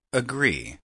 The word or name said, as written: agree